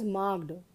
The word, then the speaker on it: marked: Students, I hope you know that, like, M-A-R-K-E-D, it is not pronounced as marked. You know, when the verb is used as an adjective, then we always enunciate the ed, that is marked.